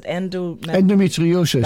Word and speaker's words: endometriosis: Is it endometriosis? – That's the one.